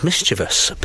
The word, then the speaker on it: mischievous: One that always gets me is mischievous. People pronounce it mischievous or mischievous when it's actually mischievous.